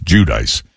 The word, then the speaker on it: Giudice: Well, the first time I saw the name, I pronounced it like the Italian way, just assuming that that's how she pronounced it. But I never watched the show, so I found it. Somebody corrected me. It might have been you who corrected me, saying, no, it's actually pronounced Giudice.